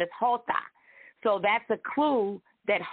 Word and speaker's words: jota: No, it's not. It has the H sound. J is the letter Jota.